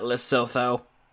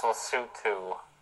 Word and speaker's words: Lesotho: 93, Lesotho. – Lesotho? – Yeah, and yes, that's how you say it, not Lesotho. – It's Lesotho.